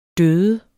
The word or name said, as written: død